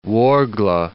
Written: Ouargla

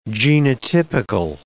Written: genotypical

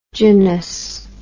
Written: gynous

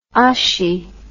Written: hachis

